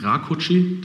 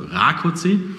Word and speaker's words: Rákóczi: Und mir als Hofmarschall wurde ja letztes Jahr dann noch eingetrichtert, das heißt also Rákóczi, die Betonung liegt auf dem A.